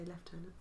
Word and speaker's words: lieutenant: Americans say lieutenant, we say lieutenant.